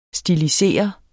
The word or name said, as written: stilisere